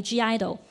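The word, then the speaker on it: (G)I-DLE: 不过 just in case，因为 Esther 有轻轻问过我，就话其实 G idol 系咩嚟噶咁样啦。咁所以咧我就讲一讲啦咁。咁我就讲下啦。咁话说咧喺韩国咧就有对女团个名叫 G idol 啦。咁个写法唔系咁样写嘅，不过咧个发音同样都系叫 G idol，而咧佢已经。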